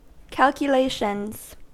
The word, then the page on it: calculations: American English